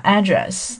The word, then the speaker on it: address: alley, avenue, street, road, residential college, postcode, shared, occupant, OK，那么今天的这组词里面呢，我讲两个内容，一个是地址的发音，地址的发音可以有两个，一个是什么，address 或者是什么 address 都可以哈，就是要看你是怎么样那个语言习惯。